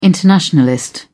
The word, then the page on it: internationalist: British English